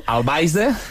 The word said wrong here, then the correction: Alvise: Eso es correcto. Bastante mal pronunciado, pero es correcto. Alvise Pérez.